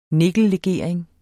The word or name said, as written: nikkellegering